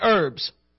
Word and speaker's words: herbs: Now, in Genesis chapter 1, you see that it just specifically focuses on the tree that bears fruit and herbs. Herbs, however you pronounce it. My understanding is that the H is silent.